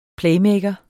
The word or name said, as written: playmaker